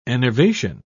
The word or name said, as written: enervation